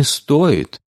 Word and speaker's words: стоит: «Не стоит», — ударение можно ставить хоть на «о», хоть на «и».